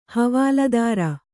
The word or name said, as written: ಹವಾಲ್ದಾರ